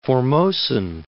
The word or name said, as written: Formosan